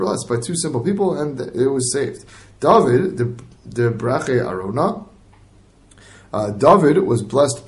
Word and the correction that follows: Aravna: Sorry, I'm sorry, or Aravna. I'm so sorry. Yeah, Aravna. Nachon, Aravna is how you pronounce it, right?